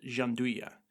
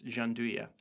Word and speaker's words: gianduja: chopped hazelnuts called gianduja, which I might be saying that wrong, but from what I can best guess, gianduja.